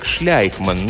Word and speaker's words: Шлейкман: Уильям Шлейкман произносил свою фамилию как Шляйкман, но многие из тех несчастных, которым он делал ремонт, называли его не иначе, как Шлюхман.